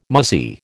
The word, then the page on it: muzzy: American English